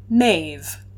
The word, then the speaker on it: Maeve: So together the last two letters make the V sound. That's right, my peeps. Mab's original name is pronounced Maeve, Queen Maeve of Connacht.